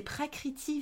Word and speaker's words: Prakriti: Euh, et d'ailleurs, oui, est-ce que tu peux nous parler Alors, j'espère que je le dis bien, du c'est Prakriti, Vrikriti, c'est ça Ouais, c'est ça.